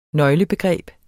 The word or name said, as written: nøglebegreb